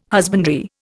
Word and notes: husbandry: Indian

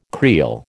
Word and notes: creel: American English

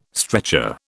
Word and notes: stretcher: British English

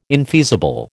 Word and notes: infeasible: American English

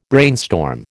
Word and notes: brainstorm: American English

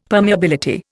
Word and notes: permeability: Indian

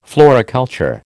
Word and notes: floriculture: American English

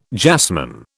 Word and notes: jasmine: British English

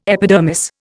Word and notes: epidermis: Indian

